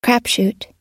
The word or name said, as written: crapshoot